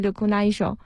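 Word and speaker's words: 緑内障: 当然日文它发音是 lukunaisho、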